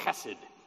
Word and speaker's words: chesed: Everybody say chesed. Yeah, isn't that wonderful? You get a nice guttural sound and a two-syllable word that in modern English of the 17th century, we have to say loving kindness.